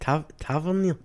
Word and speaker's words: Tavernier: Do you think James Tavernier is another one that other clubs might be looking at, Scott? – How do you say it? Tavernier? – Tavernier.